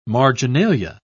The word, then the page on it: marginalia: British English